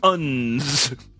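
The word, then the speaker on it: UNDZ: Starting March 15th, men who purchase three or more pairs of UNS underwear, that's capital U, capital N, capital D, capital Z. It's pronounced UNS. Right, if you purchase three or more pairs of UNS underwear via the company's website,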